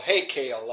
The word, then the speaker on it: heykal: applied to the Jewish temple was H-E-Y-K-A-L, Heykel, I would assume. Is that right? All right. I'll do it better. – Close enough.